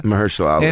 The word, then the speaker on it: Mahershala Ali: Mahershala Ali, and I'm like, who the fuck is that other guy?